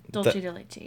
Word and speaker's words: Dulce de leche: How would you say it again? – Dulce de leche, yeah.